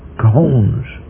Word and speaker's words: Khons: Khons. I exaggerate the pronunciation because it really is a vowel sound with an aspirant pronunciation. You have to say Khon.